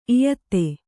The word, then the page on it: ಇಯತ್ತೆ: iyatte